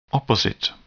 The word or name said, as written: opposite